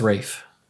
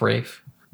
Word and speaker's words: Ralph: It's Rafe. Look up how do you pronounce Rafe Fine's name. And yeah, it's spelled Ralph, but it's pronounced Rafe.